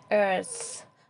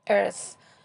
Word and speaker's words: earth: TH در زبان انگلیسی دوتا تلفظ داره. یک تلفظیست که رو به سه، صدای سه میره و دیگری رو به ز میره. اینجا earth. زبان پشت دندان‌های بالا قرار می‌گیره و اندکی هم بین دندان و صدای سه تلفظ میشه. Earth.